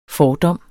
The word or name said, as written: fordom